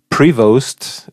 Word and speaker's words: Prevost: Prevost wäre die richtige Aussprache, das nur am Rande.